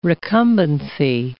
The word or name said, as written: recumbency